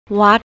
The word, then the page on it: wat: thai